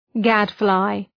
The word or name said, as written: gadfly